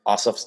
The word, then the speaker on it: Ossoff: The Purdue Ossoff, I don't even remember how to pronounce it. Ossoff, yeah.